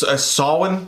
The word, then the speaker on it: Samhain: It's Samhain is how it's pronounced. They pronounced it wrong in the movie.